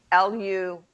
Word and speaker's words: Lugh: and this is from the god Lu, L U G H, Lu. Remember, yes. it's Gaelic. And half the letters aren't pronounced anyway.